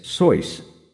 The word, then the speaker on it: Seuss: Another quick side note, the name Seuss was originally pronounced as Soyce, rhyming with voice. Later in life, he changed it to rhyme with Mother Goose.